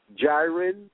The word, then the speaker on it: Jyrine: Jairon? – Yes. – Spell that. – J-Y-R-I-N-E.